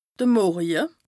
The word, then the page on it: de Morje: Bas-Rhin; Strasbourg